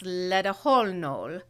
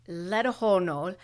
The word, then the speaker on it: Letlhogonolo: Anyway, this is a South African name and it's pronounced Ledeholnol. Letohonol, mm. and it's L E T L H O G O N O L O.